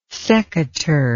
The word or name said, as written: secateurs